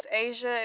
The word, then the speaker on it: Asia: My name is Asia. It is spelled A-S-I-A. – S-I-A? – Like the continent Asia, A-S-I-A.